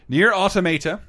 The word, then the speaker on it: Nier Automata: Nier Automata, which is how it's pronounced, I checked with the developers, is $25 at Best Buy, and Overwatch is $30 almost everywhere.